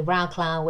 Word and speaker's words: Wrocław: Like, we went to Wrocław, which is not how you say it in Polish, but it's how you say it if you're not Polish.